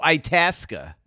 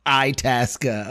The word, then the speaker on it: Itasca: Itasca? – Itasca. – Itasca.